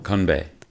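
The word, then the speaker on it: Konbae (건배): Konbae.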